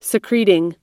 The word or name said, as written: secretin